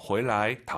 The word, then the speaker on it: 回来: 回来 다 알아들어요. 근데 어렵게 말씀을 드리면 回 뒤에 있는 来나 去는요. 경성입니다. 방향보호로서. 돌아오다. 回来 해도 되지만 정확한 발음은 回来.